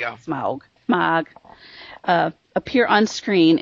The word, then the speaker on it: Smaug: Our conundrum is will Smaug, and I hope I said that right, because I know once in a while I say smog, but I mean smaug.